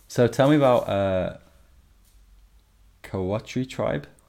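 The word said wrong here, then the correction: kōwhai: I hope that I say this correctly, but in Māori, W and an H is pronounced an F, I think. So it's Ko-Fi.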